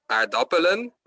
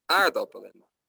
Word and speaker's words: aardappelen: Dus de klemtoon is bijvoorbeeld, het is niet aardappelen, maar aardappelen. Of niet ananas, maar ananas.